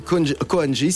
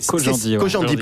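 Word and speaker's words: Khojandi: Alors, Kian Kojandi, c'est... – Kojandi, pardon. – C'est ça. – Exactement. – C'est la bonne prononciation.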